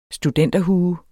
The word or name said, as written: studenterhue